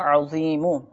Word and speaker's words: عظیم: یا عظیم, دیکھیں اس میں تلفظ کی درستی مدنی چینل کے ناظرین بہت زیادہ ضروری ہے. عین و یا میم, یا عظیم.